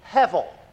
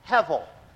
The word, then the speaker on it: Hevel: In Hebrew, it's like this, and the B is pronounced like a V, Hevel, Hevel.